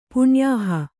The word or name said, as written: ಪುಣ್ಯಾಹ